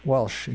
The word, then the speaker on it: Walsch: And it's pronounced, the last name is pronounced Walsh? – Yes, Walsh. – Walsh, exactly.